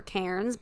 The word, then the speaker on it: Cairns: This up, it's spelled C-A-I-R-N-S. It looks really? Like Cairns or Cairns, but it's yeah. Cairns.